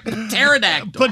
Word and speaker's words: pterodactyl: Because the pee is silent. You're laughing. You don't know why. You're laughing because you think you should. You don't know that pterodactyl starts with P, like pterodactyl. – Pterodactyl.